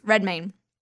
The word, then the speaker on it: Redmayne: with Eddie Raymond. – Redmayne. – I never pronounce his last name right.